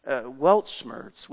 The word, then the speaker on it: Weltschmerz: I'm not a German expert, but I understand it's pronounced Weltschmerz, which literally means pained by the world.